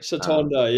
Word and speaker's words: Satonda: am I saying it right, Santoda Island? That's where you took a photo. Is that right? – Satonda, yeah.